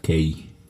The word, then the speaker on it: Kae: Kai en inglés se pronuncia Kay,